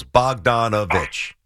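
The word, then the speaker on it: Bogdanović: Oh, Bogdanovich. Yeah. Four syllables. Bogdanovich.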